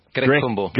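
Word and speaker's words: Grech-Cumbo: Is it Grech Kumbo? – Silent CH. Grecumbo.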